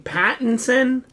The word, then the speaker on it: Pattinson: Pattinson? – Yes.